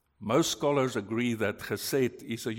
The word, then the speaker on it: chesed: H-E-S-E-D, pronounced as chesed.